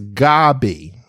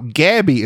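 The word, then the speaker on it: Gaby: I only have one B. It's G A B Y. I understand. I understand. That's that seems to be tickle typical, but it's it's Gabby. So Gabby is two B's, but that's okay.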